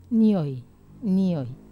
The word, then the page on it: nioi: Wamesa